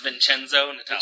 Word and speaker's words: Vincenzo Natale: I think that's Vincenzo Natale. – Vincenzo Natale. Thank you.